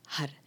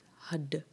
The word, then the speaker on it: har: The word is har. H A R. You either you can roll the R, r, r, r, r, r or you pronounce it as hud, d, d, d.